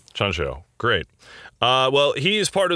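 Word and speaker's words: Tjahjanto: It's Chancho. – Oh, cool. – Yeah, it's a CH sound with the T and the J there.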